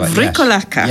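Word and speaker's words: Vrykolakas: Something called Vrikolakas. – I'm glad you pronounced that. – I think that's how you say it. Vrikolakas. – Yes.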